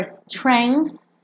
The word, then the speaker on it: strength: I'll be your strength. Strength. Tá? Esse G aqui é mudo. Então você pensa strength e bota aquele som. Tá?